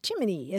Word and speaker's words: Gemini: the Federal Space Agency's official pronunciation for its new man-in-space program, Gemini, is Jiminy, as in Jiminy Cricket.